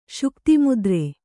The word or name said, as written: ಶುಕ್ತಿಮುದ್ರೆ